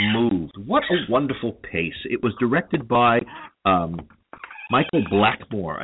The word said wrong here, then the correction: Blakemore: I believe, who is a very well-known Australian. Michael Blakemore. That's how well-known he is here. I get his name wrong.